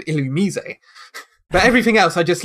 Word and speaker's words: Illumise: I can't even, because most people would pronounce it Illumise. – Yeah. – Or Illumise or Illumise. But it's Illumise.